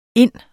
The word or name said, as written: ind